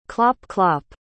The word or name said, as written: clop-clop